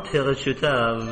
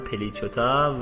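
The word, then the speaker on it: Plichota: من نام ها رو به فرانسوی تلفظ میکنم که شما یاد بگیرید، به حال شاید روزی گذارتون افتاد بتونید دقیق تلفظ بکنید، آن پیغشوتا و سنگین زخف، که حالا اسمشون به فارسی میشه آن پلیچوتا و سندرین وولف.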